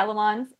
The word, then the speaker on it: Le Mans: Do you pronounce it Le Mans? – Yeah, Le Mans. – Yeah, Le Mans.